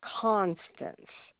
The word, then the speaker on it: Constance: Oh, Constance.